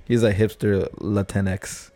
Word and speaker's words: Latinx: Latinx.